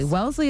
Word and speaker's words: Wellesley: You're doing it right, probably. Wellesley is the right way to say it. But some people, they say, is the common pronunciation is Wellesley. – So Wellesley. No, it's Wellesley. – It's Wellesley. Wellesley.